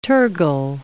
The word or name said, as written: tergal